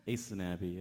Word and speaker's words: Isanabi: Isanabi, is that pronounced well? – Isanabi, yeah.